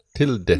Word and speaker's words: Tilda: How are we pronouncing that? – Tilda.